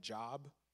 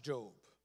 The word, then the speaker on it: Job: When I first became a Christian, I called it Job. Many of you have been there too. Don't laugh at me. But it's pronounced Job.